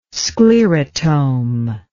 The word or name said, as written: sclerotome